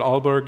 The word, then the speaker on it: Aalborg: You also hold positions in Denmark, including visiting researcher at Aalborg University.